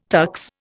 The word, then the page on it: tux: Indian